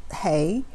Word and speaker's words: He: It's pronounced He, but it's spelled H-E.